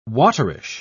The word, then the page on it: waterish: British English